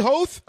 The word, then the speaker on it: Hoth: I say Hoth? Okay. – Yeah, that's the Lucas pronunciation, yeah.